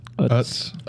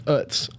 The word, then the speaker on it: Utz: Utz. – Utz.